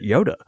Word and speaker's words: Joda: I think he's actually saying, though, the way he phrased that, Bo, I think he's actually saying that it sounds like Yoda, that you're supposed to pronounce it Yoda. He says, also, yes, it is Pastor Yoda sounds like Yoda. Like he's saying, no, you pronounce it that way. – Interesting. So you're saying Joda, like the J is silent.